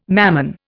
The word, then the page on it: Mammon: Indian